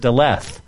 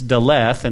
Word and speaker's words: Daleth: This is known as Daleth. Daleth, okay? And it almost sounds like death. There's a D in it, but it's Daleth. And some people say Dalet. Maybe the H is silent.